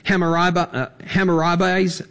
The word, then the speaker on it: Hammurabi: We have Hammurabi's famous code.